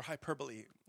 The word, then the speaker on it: hyperbole: It's hyperbole or hyperbole, if you want to say it right.